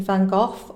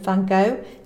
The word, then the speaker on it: van Gogh: And there are some examples of painters, for instance, Vincent van Gogh or Vincent van Gogh, depending on where you are in the world and how you prefer to pronounce it, whose genius really wasn't discovered and celebrated when he was alive.